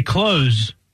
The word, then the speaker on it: clothes: I always say clothes. What do you put? You put some clothes on? – The word has only one syllable. The E is silent and the S makes a Z sound.